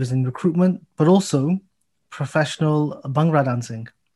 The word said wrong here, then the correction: Bhangra: Bhangra. BH is a buh sound. It's Bhangra.